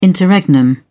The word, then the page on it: interregnum: British English